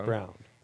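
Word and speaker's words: Braun: Brown? Christian Brown. – We pronounce it Brown.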